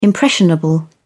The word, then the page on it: impressionable: British English